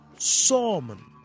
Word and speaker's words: salmon: The French, as was their wont, had swallowed up the Latin L in their pronunciation. So by the time we English borrowed the word, it was salmon. No L in the spelling, and so no L in the pronunciation. But the Latin speakers pronounced it with L.